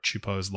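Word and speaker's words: Csupo: They are both series created by Klasky Chupo. Which is the pronunciation of Gabor Chupo's last name.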